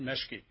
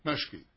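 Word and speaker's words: Meschke: M-O-S, is it I-K-E? Or? – It's M-E-S-C-H-K-E, and pronounced Meshke. – Meshke, all right.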